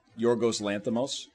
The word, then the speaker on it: Yorgos Lanthimos: One of the things that gets me is I really like this director, Yorgos Lanthimos, whose name I finally started pronouncing correctly.